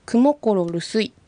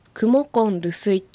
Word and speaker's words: クモコンルスイ: クモコロルスイと発音するよりはクモコンルスイと発音した方がよりアイヌ語らしい発音だと思います。